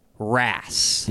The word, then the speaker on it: wrasse: How do you spell it? – W-R-A-S-S-E. Rass.